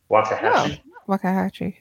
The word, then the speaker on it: Waxahachie: I don't know if I'm going to pronounce it correctly, but Waxahachie. – Waxahachie, yeah.